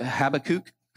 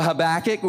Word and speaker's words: Habakkuk: Some of you pronounce it Habakkuk. But Habakkuk, we're going to take you to Habakkuk, who was a prophet.